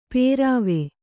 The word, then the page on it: ಪೇರಾವೆ: pērāve